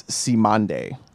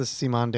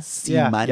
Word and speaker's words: Cymande: I think it's Simande. – Yeah, I think it's the Simande as well. – Yeah. C-Y-M-A-N-D-E. Are you familiar? – C-Mande.